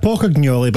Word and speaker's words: Pocognoli: New Scotland manager, Sebastian Pocognoli. I don't know if I said, did I say that right? Am I getting a thumbs up for that? – Pocognoli, Pocognoli is Belgian.